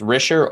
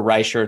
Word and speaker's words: Risher: And they brought David, I think it's Risher or Reischer. It's R-I-S-H-E-R. Sorry if I'm mispronouncing it. I'm going to say Risher.